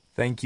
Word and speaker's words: thank: Para dar las gracias puede decir thank you, gracias, o bien de manera más amigable, thanks, thank you very much, o bien many thanks, muchas gracias. La th, the thank, se pronuncia como una S con la punta de la lengua entre los dientes.